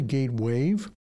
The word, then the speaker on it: Gatewave: It's really Gatewave, G-A-T-E-W-A-V-E, like a sound wave or ocean wave.